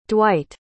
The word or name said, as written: Dwight